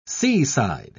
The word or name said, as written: seaside